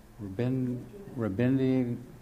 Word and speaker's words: Rabindranath: Rabindranath.